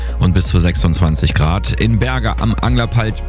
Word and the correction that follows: Börslerwald: Börslerwald, so wird es richtig ausgesprochen, haben wir gerade 19, Rade an der Heimatstube 20 und auch Heinsen an der Kiesabgrabung 20 Grad.